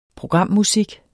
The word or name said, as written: programmusik